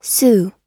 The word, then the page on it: sue: American English